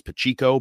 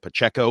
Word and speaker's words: Pacheco: But I think offensively, one of the things that I think is a difference maker for them is Isaiah Pacheco, or say down my way in the New Bedford area, it's Pacheco, but we'll call him Isaiah Pacheco because that's how he wants it pronounced.